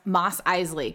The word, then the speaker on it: Mos Eisley: Kylo's getting low on at that bar on Tatooine, which my brother tells me is pronounced Mos Eisley Cantina.